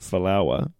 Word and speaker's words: Folau: So, Israel Folau set, or as you pronounce it, Folawa, set to return to Australia with the NRL.